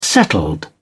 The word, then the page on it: settled: British English